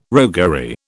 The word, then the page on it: roguery: British English